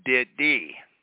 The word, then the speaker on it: Diddy: better known as Diddy, which rhymes with titty.